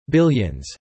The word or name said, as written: billions